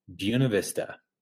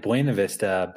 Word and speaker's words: Buena Vista: It's spelled like Buena Vista, but since there's a lot of really white people here, they pronounce it Buena Vista because they don't give a shit. – I was going to say, I think you pronounced it wrong. – No, they call it Buena Vista because it's supposed to be like beautiful view, beautiful Buena Vista.